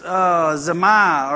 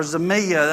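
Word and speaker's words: zemia: This loss that he's talking about right here, that word is zamia or zamia. That's how it's pronounced in the Greek.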